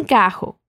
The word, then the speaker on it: carro: Entonces, el sonido de la doble R en portugués es como la J en español, un sonido de la garganta, carro.